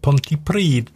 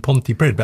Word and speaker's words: Pontypridd: Whenever I followed match reports in rugby, it's been Pontypridd. That's maybe English ignorance. – I think it's Pontypridd.